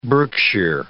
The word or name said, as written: Berkshire